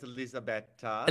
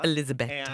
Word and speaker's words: Elisabetta: I think in Italian it's Elisabetta. – Elisabetta.